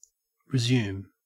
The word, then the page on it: resume: Australian English